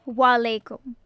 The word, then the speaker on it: Wa alaikum: Wa alaikum. – Wa alaikum.